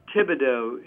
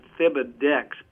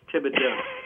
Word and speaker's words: Thibodeau: It's like Thomas. It's Thibodeau. It's very, very hard to pronounce. It looks like Thibodex, but it's Thibodeau.